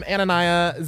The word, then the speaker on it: Anania: I am Anania 00. You spell it A-N-A-N-I-A 00.